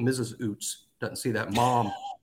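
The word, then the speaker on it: Ouzts: Robbie Oots, am I saying that right? I always screw it up. – Yeah, it's Oots. Yeah, they spelled it wrong.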